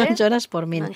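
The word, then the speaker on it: Non choras por min: Mira, he puesto de fondo esta canción Nonchoras por Min, ¿lo digo bien? – Sí, perfectamente. – Nonchoras por Min.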